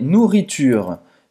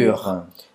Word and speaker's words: nourriture: Comida é nourriture.